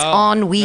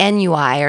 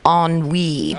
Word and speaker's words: ennui: And then you pronounced ennui incorrectly. It's ennui. – Thank you. You said like NUI or something, but it's pronounced ennui.